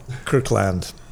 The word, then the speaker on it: Kirkland: It's I believe it's pronounced Cooklund. Cooklund signature. So let me see that. – I'm treating you really well today. – And there's a beer. – Oh, Kirkland. – Yeah. – Yeah. No, Kirkland.